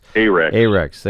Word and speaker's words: Ahrex: How do you say it? Anrex hook? A-Rex. Thank you. – A-H-R-E-X.